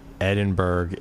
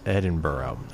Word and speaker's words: Edinburgh: But minor point, Edinburgh is pronounced Edinburgh.